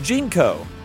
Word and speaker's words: JNCO: Mm-hmm, it's pronounced JNCO. – What did I say? – JNCO. – Okay.